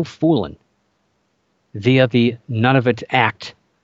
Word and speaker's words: Nunavut: Nunavut, or it's spelled N-U-N-A-V-U-T. It's very, if you look it up, people argue about what it's, how it's pronounced, but Nunavut, that's how I pronounce it.